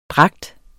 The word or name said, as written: dragt